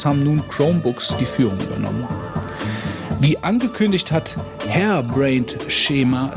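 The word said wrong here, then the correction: Harebrained Schemes: äh, Hairbrained Schemes, würde ich sagen, spricht man das aus, zeitgleich mit der Freigabe der macOS 10 und Windows-Version des Cyberpunk-Rollenspiels Shadowrun Hong Kong auch eine Linux-Variante des Titels herausgegeben.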